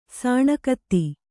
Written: ಸಾಣೆಗತ್ತಿ

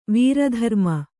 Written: ವೀರಧರ್ಮ

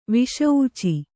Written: ವಿಷೂಚಿ

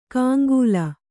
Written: ಕಾಂಗೂಲ

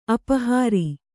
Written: ಅಪಹಾರಿ

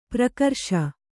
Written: ಪರಕರಿಸು

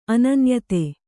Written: ಅನನ್ಯತೆ

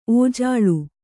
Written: ಒಜ್ಜಲು

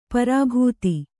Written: ಪರಿಭೇದ

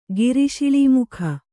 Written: ಗಿರಿಶಿಳೀಮುಖ

